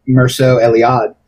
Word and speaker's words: Mircea Eliade: how do you pronounce this guy's name? Mircea Eliade? Eliade's books? – I pronounce it Mircea Eliade, so. – I like that better, so we'll go with that.